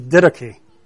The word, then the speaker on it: Didache: I think the pronunciation is Didache. And that's a Greek word.